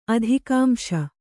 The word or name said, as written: ಅಧಿಕಾಂಶ